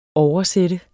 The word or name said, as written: Oversatte